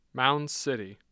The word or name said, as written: mounds city